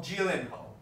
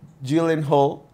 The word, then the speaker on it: Gyllenhaal: Gyllenhaal. – Gyllenhaal.